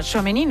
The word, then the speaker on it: Tchouaméni: El Real Madrid presenta a Tchouaméni, no sé si lo he dicho bien.